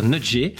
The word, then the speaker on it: nudger: Alors pour les petits pinettes, pareil, toujours qui ne connaissent pas ce que veut dire nudger. je le dis bien ou pas d'ailleurs parce que ah, c'est très bien.